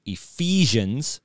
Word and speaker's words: Ephesians: Number one, Ephesians. Did I say that right? – Yeah. Okay, very good.